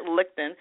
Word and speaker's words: Lichtin: I'm going to say, I said that right, Lichtin, because I want to do the CH. Yeah, absolutely. You got it. – I got it right. Jared Lichtin.